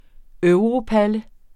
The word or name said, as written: europalle